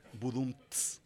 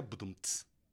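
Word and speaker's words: Budumtz: Mi segundo apellido es Budumts. Sí, con TZ. Se pronuncia Budumts.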